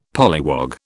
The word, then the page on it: polliwog: British English